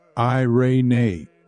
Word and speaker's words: eirene: The Greek word eirene is pronounced eirene, meaning peace, prosperity, quietness, rest, or to be set at one with yourself again.